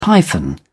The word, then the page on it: python: British English